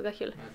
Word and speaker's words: Gaxiola: Gagiola. – yo No, se escribe Gaxiola pero es Gagiola.